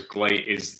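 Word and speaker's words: GLEAT: Whereas Glate is the Japanese pronunciation of great,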